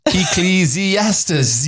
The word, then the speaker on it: Ecclesiastes: By the way, Ecclesiastes is pronounced Ecclesiastes.